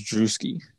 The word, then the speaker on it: Drewski: Drewski, how do you say his fucking name? – I think it's, I'm pretty sure it's Drewski.